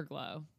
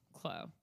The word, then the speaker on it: Uniqlo: Is it Uniqlo or Glow? – Clo. With the Q.